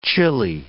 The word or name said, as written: chili